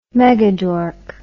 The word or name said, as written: megadork